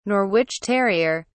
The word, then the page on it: norwich terrier: British English